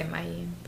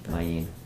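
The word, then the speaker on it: Mayene: I say Mayen. – Mayen, perfect, that works.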